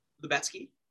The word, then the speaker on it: Lubetsky: Lubetsky.